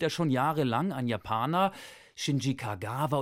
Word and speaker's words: Shinji Kagawa: Aber auch da hat die ARD-Aussprachedatenbank eine ganz klare Vorgabe, wie der heißt. – Shinji Kagawa.